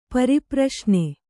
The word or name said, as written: ಪರಿಪ್ರಶ್ನ